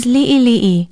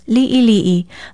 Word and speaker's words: li'ili'i: Be sure to include the diacritical pronunciation mark, the backwards apostrophe called the okina, and spell it L-I-Okina-I, L-I-Okina-I. It means small, little, in bits, or few. If you spell it right with the diacritical pronunciation marks, it is easier to pronounce li'ili'i.